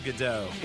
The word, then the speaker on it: Gadot: Is it Godot? How do you say her last name? Gal? – Gal Gadot.